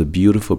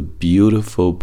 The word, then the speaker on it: beautiful: 这句话里面单词呢都比较简单，但注意一下单词 beautiful 的发音，beautiful。注意 t 和 i 稍微浊化一点，beautiful 啊，介于 t 和 d 之间，beautiful，beautiful。还有一个是自然略去 supposed to be 中的 supposed 最后这个浊辅音的 d 的发音，supposed to be，supposed to be。OK，一起练习一下这个句子。We all grow into the beautiful person that we're supposed to be.